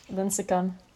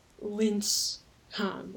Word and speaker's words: Lincecum: It's not like Lincecum. Like it's not. – No, you pronounce like the E. Like the E has a sound. – Oh, okay. – Yeah, like the second. No, the E. The only E. It's like three syllables.